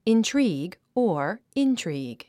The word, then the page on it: intrigue: intríːɡ; インチョリーグ